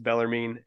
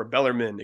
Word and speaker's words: Bellarmine: Thursday night, we hosted Bellarmine and tied the game, or Bellarmine, excuse me, common mispronunciation, tied them 0-0 in a game that we flat out dominated for most of the second half and should have won.